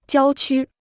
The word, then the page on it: 郊区: jiāo qū